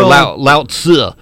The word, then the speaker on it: Lao Tzu: At least that's Lao Tzu or Lao Tzu. You kind of swallow the Z.